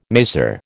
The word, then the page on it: maser: American English